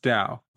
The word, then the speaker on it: Tao: The pronunciation of Tao is often written as Dao due to its origin in the Chinese language, where the character for Tao is pronounced Dao. While Tao is a more common term in English, Dao is considered the more accurate pronunciation.